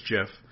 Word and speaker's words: GIF: So the actual guy who created it says it's Jif.